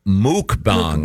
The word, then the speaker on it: mukbang: No, the word, and it's pronounced mukbang.